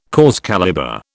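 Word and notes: cause célèbre: British English

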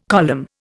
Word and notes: column: Indian

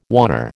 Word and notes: oner: American English

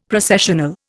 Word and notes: Processional: Indian

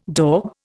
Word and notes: daub: Indian

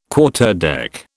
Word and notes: Quarterdeck: British English

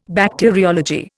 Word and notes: Bacteriology: Indian

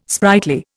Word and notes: sprightly: Indian

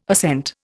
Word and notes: ascent: Indian

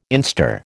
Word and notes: instar: American English

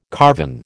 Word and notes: carven: American English

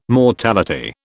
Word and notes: mortality: British English

